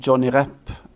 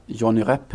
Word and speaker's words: Johnny Rep: Johnny Rep, och jag uttalar på franska, Johnny Rep heter han väl på nederländska antar jag spelare i det vackraste fotbollslag som någonsin funnits, det vill säga Saint Etiennes lag på 70-talet.